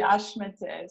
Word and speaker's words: HMÉTÉ: Yeah, you say HMÉTÉ, so it's like it's a French pronunciation.